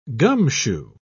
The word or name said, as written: gumshoe